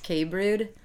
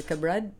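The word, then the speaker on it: Kabrud: Funniest part is my last name is pronounced K Brood. And I think it's great that I ended up in the beer industry, like Brood. – Oh, yeah. – Right? No one could ever pronounce it, but now I'm like, you know, Brood, like a beer.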